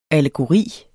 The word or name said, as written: ALLEGORIER